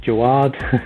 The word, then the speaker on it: Jawad: If you're my mother, then my name is Jawad. So that's the correct pronunciation. However, all my life I've just been called Jawad because that's how you kind of spell my name.